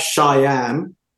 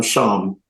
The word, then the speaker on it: Shyam: Well, just to let everybody know, because now my post has gone to so many millions, I am not Shyam. It's spelled Shyam, but I'm Shyam, like charm, nice, happy.